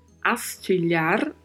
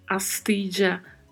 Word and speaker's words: astilla: Quanto à pronúncia, chamo a atenção aí para o duplo L. Como já mencionamos em outros podcasts, tem um som que varia de acordo com as regiões e os países. Som de DJ, som de J, de X ou CH, som de LH. Por exemplo, astija, astija, astixa, astilha.